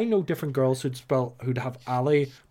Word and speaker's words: Ally: I believe that's A-L-L-Y. Ali, like short for Allison.